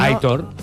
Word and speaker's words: Aitor: Aitor. – Aitor dice la acentuación es en la O. – En la O. – Claro. – Aitor. No, Aitor, venga, fuera del bar con Aitor